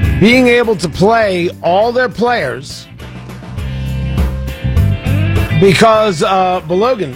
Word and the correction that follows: Balogun: Tony, it is Balogun. Hello, Balogun. – Wait. Pronounce his first name for me. – I don't know. I just say Flo. – Okay. It's Balogun, not Bologan? – Hello, Balogun.